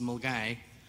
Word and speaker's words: Milngavie: It's a curious Scottish town, spelt Milngavie, but pronounced Mulgay.